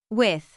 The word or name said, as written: with